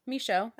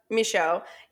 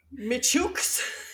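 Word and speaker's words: Micheaux: It's totally a French last name, so it's M-I-C-H-E-A-U-X. – Michaud. – That's Michaud. Yeah, I Googled the pronunciation of it. Because I didn't want to go Michooks. I could tell it was not going to be right.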